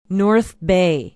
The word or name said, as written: north bay